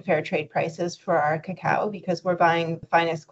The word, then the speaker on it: cacao: I learned something already because I was always pronouncing the pot as cacao. – Right. Cacao is the kind of Spanish way of saying it, and that's how most people pronounce it in the industry.